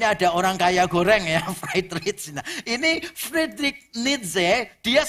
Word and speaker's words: Friedrich: Nah kalau seorang yang bernama Friedrich Nietzsche, ini bacanya Friedrich Nietzsche, orang Jerman. Jadi ini jangan dibaca pakai bahasa Inggris ya. Kalau dibaca pakai bahasa Inggris, nama depannya agak lucu. Fried rich.